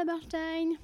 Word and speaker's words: Bursztein: Bon épisode Bonjour Rosa Bernstein. Bonjour Christine Verrou. Après plusieurs années d'amitié, c'est le moment de te demander comment se prononce vraiment ton nom. Mais tu l'as très bien prononcé.